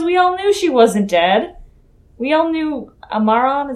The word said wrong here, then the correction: Amara: is that how you say it? – Amara. – Yeah. Amara.